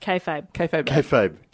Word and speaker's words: Kayfabe: Did I say Kayfabe? No. – No, you said kayfabe. – Okay, good. – You said it right for the first time because you said it wrong every single time last week.